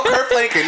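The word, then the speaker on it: Kirk Franklin: And there you go, Kurt Franklin.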